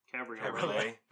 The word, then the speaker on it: Cabriolet: No, but she did have a VW Golf Cabriolet. I think that's how you say it. – Cabriolet.